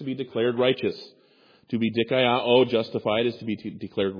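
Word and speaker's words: dikaiō (δικαιόω): The Greek word that James uses for justified is dikaiō. It's spelled D-I-K-A-I-O-O.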